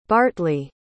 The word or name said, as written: bartley